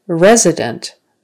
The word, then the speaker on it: resident: So let's go on to resident. Resident. Now it's on the first syllable.